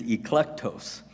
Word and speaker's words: eklektos: The Greek word used by Jesus Christ here for chosen is eklektos, and it's spelled E-K-L-E-K-T-O-S, eklektos.